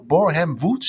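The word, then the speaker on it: Borehamwood: Tegen Bor, Borham Wood, zeg ik dat goed? – Ja half zeven.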